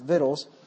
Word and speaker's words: victuals: For then had we plenty of victuals, and that's actually how that word is pronounced, victuals, and were well and saw no evil.